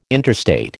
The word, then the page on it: interstate: American English